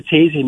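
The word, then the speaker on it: Taizé: And you also did a booklet on, am I saying this right, Taze? Is that how you say that? – Taze. – Yeah, okay.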